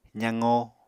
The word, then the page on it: zaqo: apatani